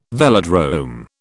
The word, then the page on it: velodrome: British English